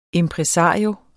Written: IMPRESARIOEN

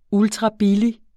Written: ultrabillig

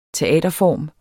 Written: teaterform

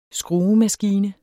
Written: skruemaskine

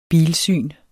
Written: bilsyn

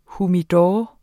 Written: humidor

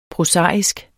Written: prosaisk

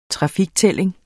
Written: trafiktælling

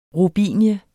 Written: ROBINIE

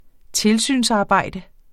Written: tilsynsarbejde

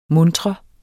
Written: muntre

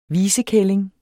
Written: visekælling

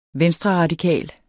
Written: venstreradikal

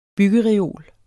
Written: byggereol

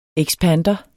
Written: ekspander

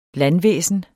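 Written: LANDVÆSNET

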